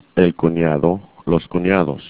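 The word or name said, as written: cuñado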